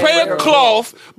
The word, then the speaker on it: prayer cloth: Ain't no player cough. I'm saying it wrong. I said player cough. – Player cough. – Wow. – I mean, you know, prayer. – Ain't no prayer cloth about to stop you from having cancer.